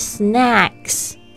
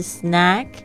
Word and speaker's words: snack: 好啦，那我们来一起学习点心，就是 snacks，千万不要念成 snake，snake 就会变成蛇的意思。它的拼法很像，但发音也截然不同，一个是 snack，一个是 snake。